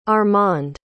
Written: armand

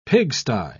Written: pigsty

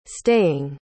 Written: staying

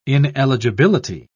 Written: ineligibility

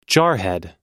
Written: jarhead

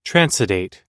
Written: transudate

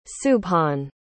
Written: subhan

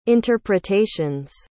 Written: interpretations

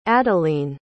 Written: adalene

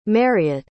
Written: Mariette

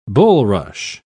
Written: bulrush

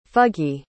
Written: fuggy

